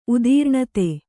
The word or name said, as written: ಉದೀರ್ಣತೆ